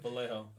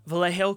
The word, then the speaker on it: Vallejo: Vallejo. – Vallejo, California.